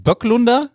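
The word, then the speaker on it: Böklunder: Bökelunder hat den Vertrag mit Schalke bis 2024 verlängert. Böglunder ist. – Warte, heißt das nicht Böcklunder? – Oh, spricht man nicht Böcklunder? – Ja, ich glaube.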